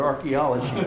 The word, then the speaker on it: archaeology: Throughout the entire book, they misspelled the word archaeology because they left out the first A, or excuse me, the second A, and they spelled it the way it sounds. You know, the A is silent. I don't know why, it's just the way it is.